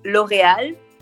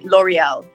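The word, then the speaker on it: L'Oréal: 呃，Well, in French is L'Oreal. – 嗯哼。 – and in English, you would say L'Oreal.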